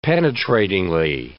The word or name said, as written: penetratingly